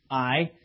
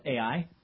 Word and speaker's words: Ai: We saw that they had suffered, that the Israelites had suffered a defeat and a loss at this, when they fought the city of Ai, or Ai, you can pronounce it either way.